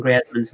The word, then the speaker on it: Griezmann: It's the same reason that everybody gets Anton Griezmann's name wrong, which also triggers me. But, you know, and it is Griezmann, by the way.